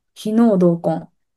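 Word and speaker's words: 皮脳同根: てちょっと、お伝えしたいなっていうところがあるんですけど、ベビーマッサージであったりタッチケアの中で、皮膚のお話をするときに非能動根っていう言葉をずっと前にちょっとこうお話ししたことがあるんですけれども、非能動根、皮膚の皮に脳みその脳で同じ根っこって書いて非能動根って読むんですけれども、水野さん記憶にありますか？ – もう忘れちゃってるかもしれないです。 – 非能動根、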